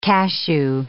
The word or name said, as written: cashew